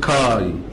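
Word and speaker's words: كاي (καί): ففي مثلا كلمه مشهوره في العهد الجديد كاتا وبعديها الفا يوتا تتنطق كاي، اللي هي الاند بالانجليزي حرف العطف يعني. فدي بتتنطق كلها على بعض كاي.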